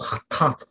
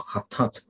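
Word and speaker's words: hatat (חטאת): 英文譯做 sin offering 啦，啊，咁但係咧，如果你睇翻個原文咧，佢嗰個讀音讀做 hatat 啊，hatat。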